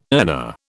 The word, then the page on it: earner: British English